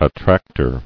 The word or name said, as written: Attractor